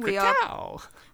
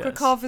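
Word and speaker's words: Kraków: Corrected our pronunciation. We are correct now. – Krakow. – Krakow is funner to say. Yes. Krakow is the proper way to say it. – Yes, yes. All the W's are V's, right? – Yeah.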